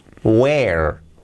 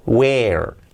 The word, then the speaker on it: where: Se escribe where, la pronunciación where, where, where. Hey, where, con la R bien marcada, where, where is, ¿dónde está?